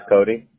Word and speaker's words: Cody: This is Cody Brooks.